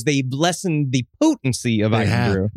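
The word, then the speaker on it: Irn-Bru: And the person I said before the show, joke of the day, gets this large bottle of Urn Brew. – It's Iron Brew. You guys are just saying it wrong on purpose.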